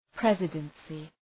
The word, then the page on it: presidency: ‘prezıdənsı